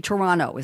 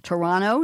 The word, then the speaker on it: Toronto: Oh, it's supposed to be Toronto. Is that how you pronounce it? Toronto? Yes, I should know. I have a lot of Canadian friends. Toronto, New York City. We all say that the same way.